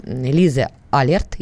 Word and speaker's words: Лиза Алерт: И вот, например, Лиза Алерт, если я правильно ставлю ударение. – Правильно.